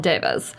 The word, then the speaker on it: devas: Devas.